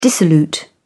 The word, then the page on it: dissolute: British English